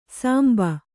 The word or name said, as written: ಸಾಂಬ